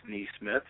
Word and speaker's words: Nesmith: Okay, well, first, you did pronounce it correctly. It is pronounced Nesmith, so very good job by you.